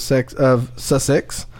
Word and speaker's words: Sussex: Students and parents have slammed the University of Sussex.